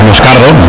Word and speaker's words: Moscardo: Entonces, sí, como bien ha pronunciado Unai, es Moscardo, brasileño,